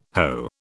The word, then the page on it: hoe: British English